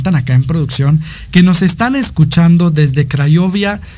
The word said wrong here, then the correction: Craiova: mm, eh, Rumania, perdón, eh, Craiova, perdón, Rumania, ya no, sé ni pronunciarlo.